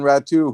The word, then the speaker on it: Ratu: Perhaps you can tell the listeners how to pronounce Ratu. Did I get that part right? – You did. You did indeed.